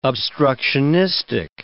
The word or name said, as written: obstructionistic